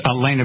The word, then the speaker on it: Halyna: We got to talk about the Alec Baldwin shooting of Helena Hitchens. Is that right? H-A-L-Y-N-A, Helena? – I think it's Elena because she's Russian. – Right. – So she'll leave the silent H, but I know it's a tough deal.